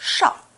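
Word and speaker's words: Thao: Now, in English, the name is spelt with a T-H, but as we've seen, it's pronounced... – Shao.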